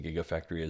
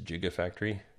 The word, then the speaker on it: Gigafactory: A lot of news outlets were pronouncing the Gigafactory as Gigafactory.